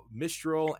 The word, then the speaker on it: Mistral: The first thing to, know is it's pronounced Mistral, and uh, it's a subtle nuance, but the name comes from a wind in Europe that comes from the north and uh, and packs a wallop.